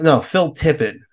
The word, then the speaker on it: Tippett: Phil Turpit, Mad God. Is that it? Is that how you say his name? Phil Tippett. That's it.